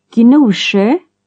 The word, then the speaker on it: Gnosjö: GN at the beginning of a word is G plus N, unlike English. – Gnosjö.